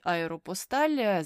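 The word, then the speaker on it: Aéropostale: що французькою мовою це вимовляється як аеропосталь, аеропосталь. А от американці чомусь кажуть аеропостал, але французькою це аеропосталь.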